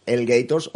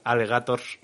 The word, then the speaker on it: Alligators: No sé si sabéis que, aparte de, darnos lecciones sin condescendencia, es eh, guitarrista de la excelente banda El Gators, o Alligators, o como queráis llamarlo, ¿cómo se pronuncia? Pues sería Elgators, pero ni nosotros mismos lo hacemos, así que al final Alegators.